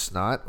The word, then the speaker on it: $NOT: I've heard this song called Whipsky by this artist called, I think his name is pronounced Snot or dollar sign N-O-T.